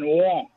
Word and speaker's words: Rouen: If you want to know the town Joan of Arc was put to the torch at, is Rouen. – Orléans. – No. She's from Orléans. She was put to the torch in Rouen. That's R-O-U-E-N. R-O-U-E-N.